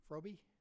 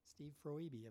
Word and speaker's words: Froebe: And we've got, let's see, is Steve Froby? You're here. Did I pronounce that correctly? Is it Freebie or Froby? – Froebe. – Oh, he split it there. Okay, it's got three syllables. Okay. Steve Froebe of Big West Oil.